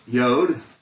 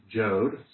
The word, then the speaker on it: Yod: Normally pronounced Yod. Former times people pronounced it Jod. Sometimes people pronounce it Iod.